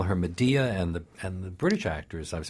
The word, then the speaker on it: Medea: Well, what if I said here in the back, this wonderful edition, I pronounce it Medea.